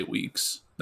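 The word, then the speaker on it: Weekes: I'd say Weeks. That's it's W-E-E-K-E-S, yeah. Right? Okay, yeah. That's probably Weeks.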